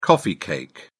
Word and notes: coffee cake: British English

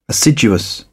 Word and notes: assiduous: British English

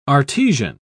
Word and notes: artesian: British English

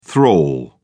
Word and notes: thrall: British English

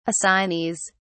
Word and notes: assignees: British English

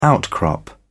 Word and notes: outcrop: British English

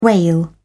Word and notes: whale: British English

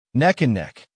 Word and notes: neck and neck: American English

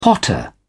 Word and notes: potter: British English

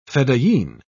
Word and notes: fedayeen: British English

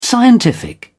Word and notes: scientific: British English

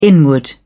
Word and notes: inward: British English

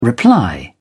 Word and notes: reply: British English

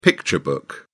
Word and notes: picture book: British English

